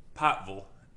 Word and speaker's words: Pikeville: Well, it's like when I say Pikeville. Mm-hmm. Pikeville is a more appropriate pronunciation. P-I-G-H-T-V-U-L.